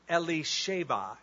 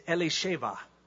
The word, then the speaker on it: Elisheba: I prefer the Hebrew pronunciation, Elisheba. Anybody here named Elizabeth? Okay, your name in Hebrew, the original pronunciation of your name, not the anglicized Elizabeth, is Elisheba.